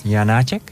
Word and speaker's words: Janáček: Van Janáček, heb ik het goed uitgesproken? Ja.